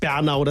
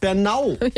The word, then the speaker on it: Bernau: Das heißt nicht Bernau, das heißt Bernau.